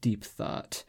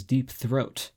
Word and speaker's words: Deep Thought: Now here's an issue with announcers yelling out Deep Thought, and it gaining so much notoriety. You see, this is my favorite part of this story. Deep Thought kept on being mispronounced as Deep Throat.